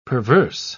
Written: perverse

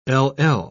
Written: ll

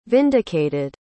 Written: vindicated

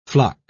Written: flock

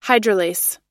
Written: hydrolase

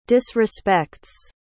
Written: disrespects

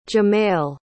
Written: jamael

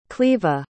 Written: cleva

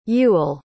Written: Ewell

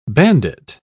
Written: bandit

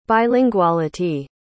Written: bilinguality

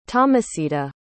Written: tomasita

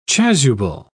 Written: chasuble

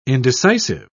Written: indecisive

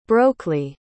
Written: brocly

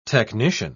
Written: technician

